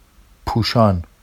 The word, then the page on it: puʃɑn: Vafsi